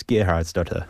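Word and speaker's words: Geirharðsdóttir: So, my first question for you is, the pronunciation master, how do you pronounce the surname of the lead actress in this film? – I believe it's Gerhard's daughter.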